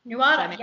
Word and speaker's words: Nuada: Nuada. – Nuada, yeah.